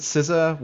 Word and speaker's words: CISA: And you pronounce it CISA, which is unlike how you pronounce pizza or CISA or anything. Like, so anyway, they have a weird pronunciation because it's government.